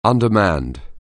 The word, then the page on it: undermanned: anglais